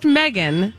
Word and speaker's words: Medghyne: M-E-D-G-H-Y-N-E. Anyone? – Medgen. – Medgen. It's pronounced Megan, but it's spelled M-E-D-G-H-Y-N-E.